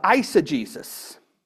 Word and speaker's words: eisegesis: There's something unpleasant called the eisegesis. And that's spelled E-I-S-E-G-E-S-I-S, which means to insert your modern beliefs into the scripture. E-I-S-E-G-E-S-I-S, eisegesis, is bad, okay?